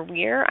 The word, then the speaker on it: Weir: And Afterphobe agrees with me that it's pronounced Weir.